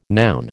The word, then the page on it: noun: American English